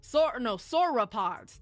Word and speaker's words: sauropods: Sauropods. That's how you say it.